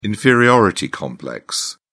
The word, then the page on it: inferiority complex: British English